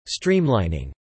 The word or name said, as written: streamlining